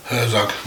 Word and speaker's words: Herzog: How the fuck do you pronounce his last name? – Herzog.